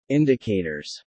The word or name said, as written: indicators